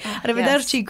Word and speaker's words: Arrivederci: Arrivederci, great pronunciation.